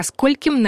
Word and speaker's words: скольким: Ко скольким нам лучше прийти? Не забываем про ударение на «о».